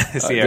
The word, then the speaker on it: Ciara: Yeah, Sierra.